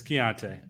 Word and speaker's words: Keontae: It's Keontae. I heard him say his name himself. – Yeah. Oh really? I didn't know that. I thought it was Kanata. – It's like not even spelled close to that.